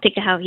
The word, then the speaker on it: Tikehau: Technology Acquisition Company will start trading tomorrow, while Pegasus Asia, with their asset manager, Tikehau Capital, is that how it's said? – Mm-hmm. Yeah. Tikehau, yes.